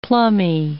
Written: plummy

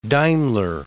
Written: Daimler

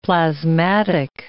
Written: plasmatic

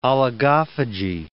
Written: oligophagy

